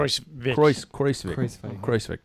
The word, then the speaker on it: Kruijswijk: But Kruijswijk was, am I even pronouncing that correctly? – Kruijswijk.